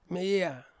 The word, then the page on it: meia: Ansus